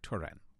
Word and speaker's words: Turin: NPR's Key Molesky, and that is how you say Turin.